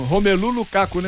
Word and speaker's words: Romelu Lukaku: 250 anos, mas como é que fala? Rapaz, o cara escora mesmo. Romelu Lukaku, né?